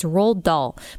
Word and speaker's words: Roald Dahl: So it's Roald Dahl, but I might have a hard time.